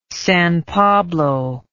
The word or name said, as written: San Pablo